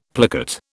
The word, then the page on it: plicate: British English